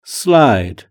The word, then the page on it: slide: British English